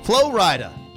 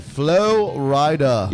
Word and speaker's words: Flo Rida: Flo Rida. – That's what I said. – He said Flo Rida.